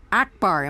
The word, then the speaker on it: Akbar: Akbar, am I saying it right? – That's it.